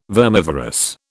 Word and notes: vermivorous: British English

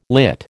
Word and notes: lit: American English

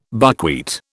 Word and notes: buckwheat: British English

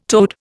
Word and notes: toad: Indian

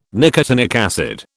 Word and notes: nicotinic acid: British English

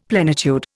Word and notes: plenitude: Indian

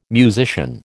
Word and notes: musician: American English

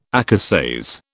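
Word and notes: écossaise: British English

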